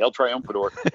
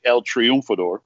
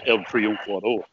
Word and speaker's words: El Triunfador: That's how us gringos say it. Actually, El Triumphador. that's me. But. Uh, but Cubans they they would they would well actually if you want to say it in the Spanish language it'd be El Triunfador and if you really want to say it like a Cuban person, it's El Triunfador.